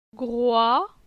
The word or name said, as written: Groi